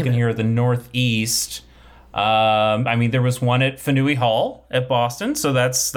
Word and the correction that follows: Faneuil: Fanui? Is that not pronounced? – Faneuil Hall. – Faneuil, okay. I'm a tourist.